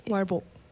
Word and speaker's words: 말복: Oh, 말복.